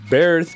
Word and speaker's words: Bairth: Lo, vamos a enlazar con Birth, pero se escribe Bairth, un proyecto de Estados Unidos con, eh, con dos pies en México.